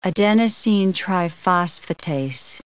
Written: adenosine triphosphatase

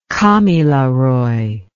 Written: kamilaroi

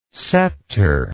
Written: sceptre

